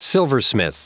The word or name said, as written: silversmith